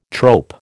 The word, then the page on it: trope: American English